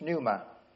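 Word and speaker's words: pneuma (πνεῦμα): In the Greek, in the New Testament, it's pneuma, with a silent P. That's where we get the word pneumatic drill.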